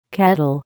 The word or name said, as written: kettle